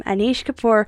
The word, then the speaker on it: Kapoor: The rivalry started, Anish Kapoor, I found out the correct way to pronounce his name, Anish Kapoor, licensed Vanta Black, and, Stuart Semple responded with the pinkest pink.